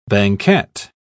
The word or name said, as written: banquette